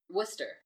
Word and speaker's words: Worcester: Worcester.